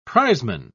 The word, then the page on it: prizeman: British English